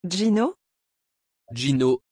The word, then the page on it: Gino: French